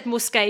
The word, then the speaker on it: Musqué: I'm glad you said Musqué because I was like, well, how do you even pronounce it? it this is spelled M U S Q U E.